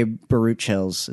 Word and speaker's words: Baruchel: you do have Jay Baruchel's, is that how you say his name? – Baruchel, yeah.